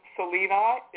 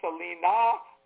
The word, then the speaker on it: Selena: My name, Selena, is an ancient Greek form of the full moon goddess. Sometimes it's pronounced Selena or Selene.